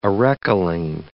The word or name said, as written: arecoline